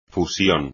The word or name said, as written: fusión